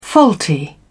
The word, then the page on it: faulty: British English